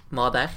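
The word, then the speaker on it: Mäder: Uh, og så eh, Gino Mader, eller kanskje om det er riktig uttale. Mm. Mader. – Jeg er ikke ekspert på sveitsisk eller fransk eller Det er spot on.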